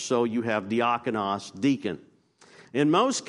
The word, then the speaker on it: diakonos: Diakonos is the word in Greek. Diakonos.